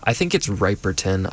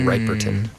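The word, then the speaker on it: Riperton: And I think it's Ripperton. I think it's not Rip, it's Mini Ripperton.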